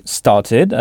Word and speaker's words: started: Ici, la terminaison ed se prononce ed. Alors, j'ai commencé à 8 heures. – I started at 8 o'clock. – I started at 8 o'clock. Oui, donc started avec le son ed.